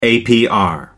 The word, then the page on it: apr: American English